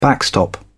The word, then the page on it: backstop: British English